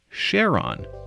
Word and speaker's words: Charon: NASA says Christie also liked the fact that the first four letters were the same as in his wife's name, Charlene, and that's why he pronounced it Charon.